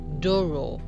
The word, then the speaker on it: Douro: Port wine can only be named port if it is made in the Douro it's spelled D-O-U-R-O. Some people pronounce it Douro, but it's not Douro. I've heard it pronounced as Doro, yeah, in the Douro region, and this area is one of the 14 wine regions in Portugal, being the oldest demarcated wine region in the world.